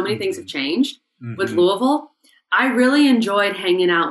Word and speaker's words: Louisville: You know, he was, when I think of Louisville, you got to say it right.